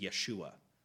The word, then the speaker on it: Yeshua: The Hebrew way of pronouncing it is Yeshua, Joshua.